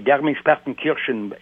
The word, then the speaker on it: Garmisch-Partenkirchen: Et c'est Garmisch-Partenkirchen, excusez-moi de le prononcer à l'allemand.